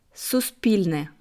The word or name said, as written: Suspilne